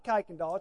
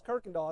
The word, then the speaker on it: Kuykendall: She goes, "It's not Kuykendall, it's Kirkendall." I said, "It's spelled Kuykendall." She said, "I ain't know how they pronounce it. They pronounce it Kirkendall around here."